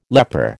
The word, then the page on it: leper: American English